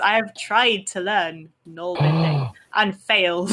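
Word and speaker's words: nålbinding: Okay, yeah, gnarlbinding. – Say it gnarlbind. I was told off by one of my subscribers for saying gnarlbind, but it's pronounced the little... It's got a little circle above the A.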